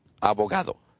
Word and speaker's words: abogado: Abogado. – El abogado.